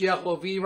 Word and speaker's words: Yehovah: And the only reason it's pronounced Yehovah right there, because the word Adonai is in front of it. And it's a pronunciation thing. So it sounds correct in the sentence.